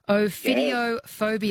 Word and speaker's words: Ophidiophobia: Ophidiophobia. Have I said that correctly? Ophidiophobia, yeah, would be the extreme irrational fear of what? – Oh.